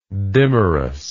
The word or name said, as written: dimerous